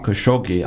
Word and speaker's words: Khashoggi: Number one, and you've already spoken about the hypocrisy of it, that Jamal Khashoggi, I'll use the Arabic pronunciation, my Turkish is really bad um, that uh, the he that, that he was in fact um, an American citizen.